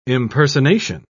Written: impersonation

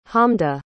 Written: hamda

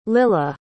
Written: lilah

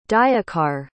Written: dayakar

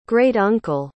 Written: great-uncle

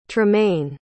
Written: trumaine

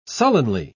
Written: sullenly

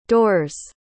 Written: door's

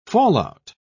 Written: fallout

